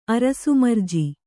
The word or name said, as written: ಅರಸುಮರ್ಜಿ